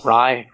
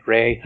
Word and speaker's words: Rai: including Ray, R-A-I. – Cool. – Rye, Ray. – Ray. There are multiple pronunciations. I've heard it both ways.